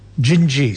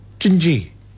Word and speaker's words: Dindi: And she's going to sing us for us this beautiful song, which is called Gingy, spelled D-I-N-D-I, pronounced Gingy.